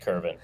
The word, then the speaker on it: Kervin: Because the pronunciation the Cubs sent out said Kervin. – Yeah, I think it was an error. It's Kervin.